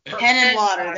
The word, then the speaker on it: Henenlotter: Okay, I never know how to say his name. Lotta Labber Ladder. – Henenlotter? – Henenlotter, there we go.